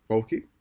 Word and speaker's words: Fauci: I believe it's an Italian name and the double C is pronounced Ch. But, you know, so Fauci. Whereas if he was in English, it would be Fauci or whatever you said.